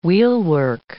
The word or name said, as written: wheelwork